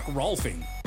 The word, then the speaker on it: Rolfing: Wednesday on Let's Talk Sports, we'll recap the NBA draft, and we'll talk golf with Mark Rolfing. – Hey, his last name, it rhymes with golfing.